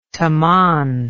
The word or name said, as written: toman